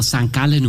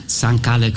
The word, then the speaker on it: St. Gallen: Ja, für alle in Bhutan oder irgendwo, wo es hört, so spricht man St. Gallen aus. St. Gallen gefällt allen.